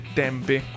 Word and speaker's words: Tempe: Allora, innanzitutto si scrive Tempi, ma si legge Tempe con la E finale.